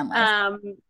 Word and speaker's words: McCandless: McCandless. – Yeah. McCandless.